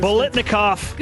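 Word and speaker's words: Biletnikoff: Bolitnikoff.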